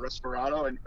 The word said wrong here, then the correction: Reposado: or I always say that wrong. – Reposado.